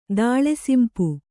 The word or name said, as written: ದಾಳೆಸಿಂಪು